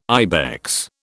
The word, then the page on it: ibex: British English